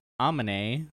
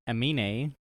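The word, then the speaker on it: Aminé: Next is Amine, with, or Amine. – That's how you say it? – Amine.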